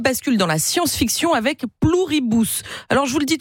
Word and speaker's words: Pluribus: Pluribus.